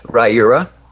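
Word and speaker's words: Riyria: Ryura.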